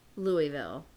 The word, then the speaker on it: Louisville: First of all, it's Louisville. They don't even know how to say it.